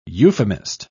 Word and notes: euphemist: British English

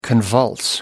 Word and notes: convulse: American English

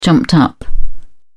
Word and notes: jumped up: British English

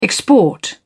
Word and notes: export: British English

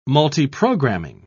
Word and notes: multiprogramming: British English

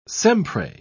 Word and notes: sempre: British English

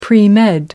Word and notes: premed: British English

